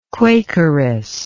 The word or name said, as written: quakeress